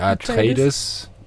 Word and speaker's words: Atreides: Eine sind die, die ich nicht aussprechen kann, die Atreides. – Atreides.